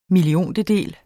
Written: milliontedel

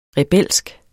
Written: rebelsk

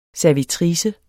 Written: SERVITRICER

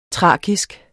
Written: thrakisk

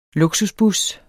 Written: luksusbus

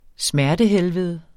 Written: smertehelvede

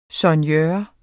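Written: soignør

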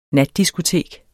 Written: natdiskotek

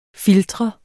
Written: filtre

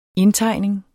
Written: indtegning

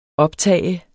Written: optager

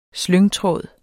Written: slyngtråd

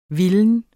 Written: vildt

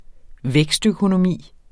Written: vækstøkonomi

